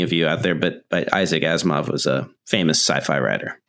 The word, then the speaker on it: Asimov: I'm going to say his name and you're going to correct me on the correct pronunciation. So it's Isaac Asimov, which I think I nailed. – I think now. You got it right.